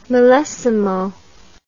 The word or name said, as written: milesimo